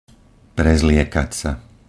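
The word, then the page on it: prezliekat sa: Slovak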